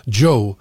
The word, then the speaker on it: Zhou: Så är det någon som hör av sig till mig återkommande att det heter Joe. Man ska uttala det Joe, som amerikanska Joe.